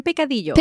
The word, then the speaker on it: picadillo: Trim picadillo.